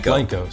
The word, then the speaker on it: Blanco: How do you say it? Blanco or Blanco? – Blanco. See? It's supposed to be Blanco.